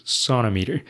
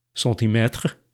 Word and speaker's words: centimeter: I looked this up on the internet, as many people apparently have, and nobody really knows who started saying sonometer instead of centimeter. But one common explanation is that the pronunciation sonometer sounds closer to the French centimètre, apparently, like, I, I don't really know if you agree with that, but in French, it's centimètre.